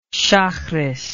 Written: shaharith

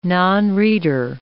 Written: nonreader

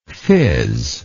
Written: fizzing

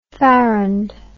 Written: farrandly

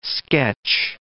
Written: sketch

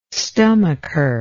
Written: stomacher

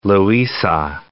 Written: Loiza

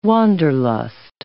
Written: Wanderlust

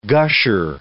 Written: gusher

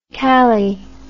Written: calli

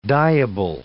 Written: dyeable

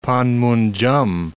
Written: Panmunjom

